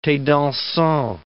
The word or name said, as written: thes dansants